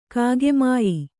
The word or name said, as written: ಕಾಗೆಮಾಯಿ